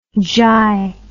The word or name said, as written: jai